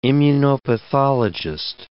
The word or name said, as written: immunopathologist